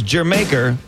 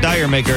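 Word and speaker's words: D'yer Mak'er: Here's a quick reminder that the pronunciation of this Led Zeppelin song is Jamaica, like the island nation of Jamaica, man, because that's where they were when they got the inspiration to record the song. It's not Dire Maker, it's Jamaica.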